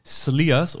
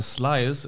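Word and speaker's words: Slyrs: Der ist natürlich am Schliersee und dieser Whisky heißt Sliers oder Slyers im Englischen ausgesprochen.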